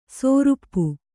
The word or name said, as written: ಸೋರುಪ್ಪು